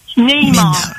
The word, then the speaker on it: Neymar: Neymar.